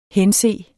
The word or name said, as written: hense